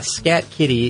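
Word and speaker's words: Skat Kitty: Now, the Scat Kitty is spelled S-K-A-T, and it's Scat Kitty.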